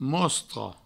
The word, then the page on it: mostra: mouostro